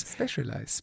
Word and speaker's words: Specialized: Specialized.